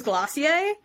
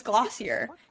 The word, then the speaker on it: Glossier: And the first brand that comes to my mind is, because I pronounced it for so long wrong, was Glossier, because it looks like Glossier.